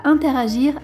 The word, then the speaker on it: interagir: Interagir, il n'y a pas d'accent. Arrêtez de placer un accent sur le E d'interagir. Et si vous y pensez, essayez de respecter la prononciation. C'est pas un E, c'est un E, interagir.